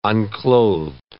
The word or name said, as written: unclothed